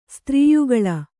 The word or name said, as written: ಸ್ತ್ರೀಯುಗಲ